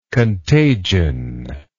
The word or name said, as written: contagioned